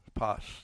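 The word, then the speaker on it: Pos (πᾶς): Justin, you can tell me if I'm saying that right. Pos, there we go, got it right.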